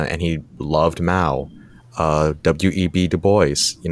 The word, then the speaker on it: Du Bois: Yeah, it's in the U.S. it's Du Bois. That's how I've heard it. But like, yeah, because nobody pronounces it the French way here.